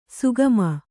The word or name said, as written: ಸುಗಮ